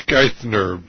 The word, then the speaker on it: Geithner: Is it Geithner or Geithner? – I think it's Geithner.